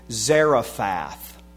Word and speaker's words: Zarephath: But unto none of them was Elijah sent, save unto, and we pronounce the word from Luke, Sarepta, but the Hebrew or the English pronunciation in our Bible is the word Zarephath.